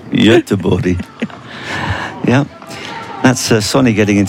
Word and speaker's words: Göteborg: Well, in English, the version is Gothenburg. But Göteborg, obviously, is how you say it in Swedish, but I gather that that's not the correct way to pronounce it.